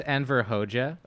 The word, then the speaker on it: Enver Hoxha: Is it Anvar Hoxha? Is that how you pronounce it? – Mm-hmm. – Okay. So famously, Enver Hoxha. – It's pronounced Enver Hoxha.